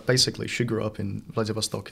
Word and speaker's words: Vladivostok: Vladivostok. Vladivostok, if you say it like in the English way. – Yeah, poorly. – If you say it poorly, it's Vladivostok.